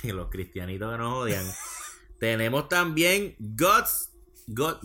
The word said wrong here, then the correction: God's Not Dead: ya lo dije mal, God's Not Dead.